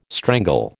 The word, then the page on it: strangle: American English